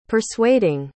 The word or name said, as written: persuading